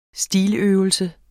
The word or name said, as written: stiløvelse